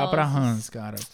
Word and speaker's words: Reims: Hans escreve R-E-I-M-S, porque às vezes é meio complicado, né? Assim, só falam Hans, como é pronúncia mais francesa. Hans escreve R-E-I-M-S.